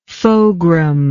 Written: fogram

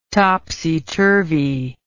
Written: topsy-turvy

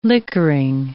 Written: liquoring

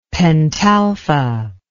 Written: pentalpha

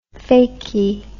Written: fakey

